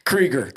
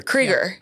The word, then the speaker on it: Kreiger: Kreiger. Kreiger.